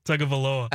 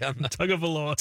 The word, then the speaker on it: Tagovailoa: Tagovailoa. – I don't think Chad said it right. – Tagovailoa. – Tagovailoa.